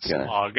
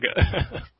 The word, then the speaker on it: Smaug: You got to say it right. – Smaug? – Yeah. It's not Smaug. Smaug.